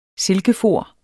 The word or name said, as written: silkefor